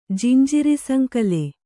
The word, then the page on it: ಜಿಂಜಿರಿಸಂಕಲೆ: Kannada